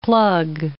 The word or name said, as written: plug